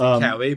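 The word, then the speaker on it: COWI: Is it pronounced Cowie or Coe, the engineers? – I pronounce it Cowie, but I've not.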